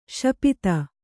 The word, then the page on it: ಶಪಿತ: śapita